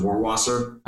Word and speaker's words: Rohrwasser: I'll look for him to be a mentor to Patriots fifth-round pick Justin Rohrwasser.